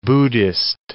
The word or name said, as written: Buddhist